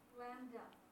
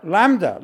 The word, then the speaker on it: LaMDA: Lambda. – What? – L-A-M-D-A. – Yeah, Lambda. – Ramba? Lambda. Lambda. Oh, L, that's L in Greek, right? Lambda.